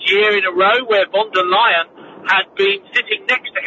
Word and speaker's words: von der Leyen: Okay, well, first of all, just on the pronunciation, it's Ursula von der Leyen.